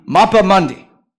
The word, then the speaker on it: Mappa Mundi: It is pronounced the Mappa Mundi.